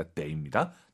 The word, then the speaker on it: 得: 수러, 즉 동사나 형용사 앞에 있으면 더가 아니라 데이입니다. 나 병원 가야 해.